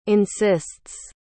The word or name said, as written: insists